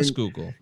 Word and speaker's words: Schuylkill: The Schuylkill. – Yeah, the Schuylkill. Yeah, the Schuylkill. – I've said it 15 different ways in my life. – Schuylkill, but I did learn Schuylkill.